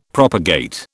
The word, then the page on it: propagate: British English